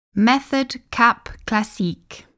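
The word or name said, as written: Méthode Cap Classique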